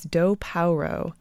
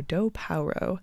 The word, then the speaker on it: Do Paoro: Her name is Do Paoro. That's spelled Do like the deer, P-A-O-R-O, Do Paoro.